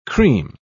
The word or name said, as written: cream